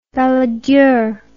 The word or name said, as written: Balladur